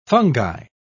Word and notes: fungi: British English

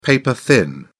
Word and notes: paper thin: British English

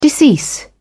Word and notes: decease: British English

